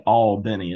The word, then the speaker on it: Albany: Well, well you pronounce it Albany. It's it's two syllables there.